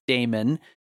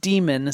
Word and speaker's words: daemon: I was thinking about this one, and I think I've always said it in my head as Damon, but I'm pretty sure it's pronounced demon, since I know that it's just a fancy archaic spelling of demon, and that the whole idea was that this is some demon that lives in your machine doing things.